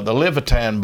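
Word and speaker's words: Olivétan: Not only that, Pierre Levitan, in 1535, when he came out with the Levitan Bible, also had the Old Latin. – Yeah, that's spelled O-L-I-V-T-A-N. – Yeah, like olive tan, but it's pronounced Levitan.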